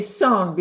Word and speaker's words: Son: I mean, we say Son because it's a Korean uh, pronunciation.